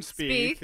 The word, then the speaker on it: Spieth: Jordan Spieth.